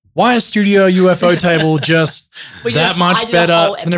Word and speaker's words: ufotable: I did a whole episode on UFO Table and I was pronouncing it Ufotable. We both were. – We've all been doing that for years. – It took one quick Google search from my partner to realize that it was UFO Table as in like, you know, unidentified flying object because apparently in The Office they have a UFO shaped table.